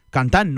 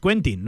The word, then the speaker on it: Quentin: Si queremos decir el apellido a lo francés, Le el nombre también, el nombre de pila también hay que decirlo, que es Cantan, no es Quentin, no es Quentin Leques.